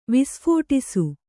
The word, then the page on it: ವಿಸ್ಫೋಟಿಸು: visphōṭisu